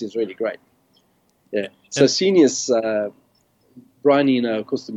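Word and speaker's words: Scenius: and is it pronounced Senius? As in, yes, I knew you were going to ask about Senius.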